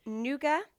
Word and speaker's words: nougat: So nougat, would you pronounce the T? I wouldn't.